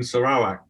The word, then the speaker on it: Sarawak: No, but that's the correct pronunciation. But if you go up to a stamp dealer and say, do you have any Sarawa? They think you might have a speech impediment. So everybody uses Sarawak. Even people in Sarawak use Sarawak.